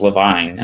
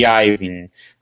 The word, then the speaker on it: Iovine: It, the I at the beginning of his last name is looks like an L, and I always thought it was just Levine, and I was like, okay, his name's Levine. And then I heard somebody actually pronounce it, and I was like, what the heck is that? And then I realized it's an I. It's IOVI, like EIN, it's just, whatever. I have no idea how to say his name, so it's gonna be Johnny Iovine, whatever.